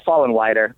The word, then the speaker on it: Follenweider: That's uh, it's close. Uh, Fallenwider, but I'm not picking.